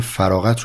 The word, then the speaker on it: فراغت: من مکرر کلمه فراغت رو اشتباه تلفظ کردم و شما به درستی بهم تذکر دادید فراغتی که من میگم با قافه و به معنای جدایی